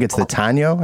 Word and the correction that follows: Taino: I think that's how you say it. – The Taino. – Taino. Okay. Yeah.